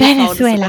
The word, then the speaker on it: Venezuela: Heißt, ja, du musst das V doch B aussprechen. Also so B, Venezuela. V, das ist ganz, diesen Venezuela. Buchstaben gibt's bei uns nicht.